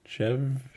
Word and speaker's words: Chèvre: How do you say it? Chèvre. Okay.